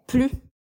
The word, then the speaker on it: plus: Como é uma negação, a gente não pronuncia o S de plus. Então, aqui, a pronúncia é plus, com o U, o biquinho do francês, e o S mudo.